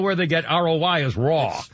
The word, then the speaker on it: Roy: And Patrick Raoul, I pronounce Raoul, it's spelled Roy, R-O-Y. Patrick Raoul, how do you pronounce it? Raoul? Patrick Raoul, the Hockey Hall of Famer, 61. – Raw. Patrick Raw.